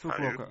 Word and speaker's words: Fukuoka: Uh, this is in uh, Fukuoka, is that right? How you say it? Fuku- Fukuoka, I believe is Fukuoka. okay, right.